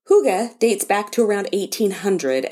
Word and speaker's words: hygge: Is it H-Y-G-G-E? – Yes. – Oh, yes. – Okay, so for everybody that has seen that word and calls it hygge, like is what I thought. – Yes. – But it's actually hygge.